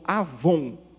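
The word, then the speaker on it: avon (עָוֹן): Mas a palavra pecado aqui, há um termo hebraico que nós o pronunciamos como avon. Não tem nada a ver com o produto que você come. Avon é a palavra.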